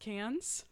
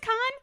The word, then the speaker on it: Cannes: It's not pronounced cans. – I don't know. I don't speak fucking French. I think it's con.